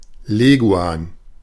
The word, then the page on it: Leguan: /ˈleːɡuaːn/